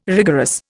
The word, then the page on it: rigorous: Indian